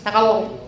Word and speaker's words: Tagalog: All Filipino people will correct you. No, you're saying it wrong. It's Tagalog. Yes, that's it.